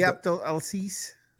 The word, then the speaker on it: Abdelaziz: How do you pronounce his last name? – What? – Abdaziz. – Ali Abdelaziz.